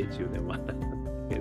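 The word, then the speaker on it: 中年不思議国: 中年ワンダーランド検索してみてください。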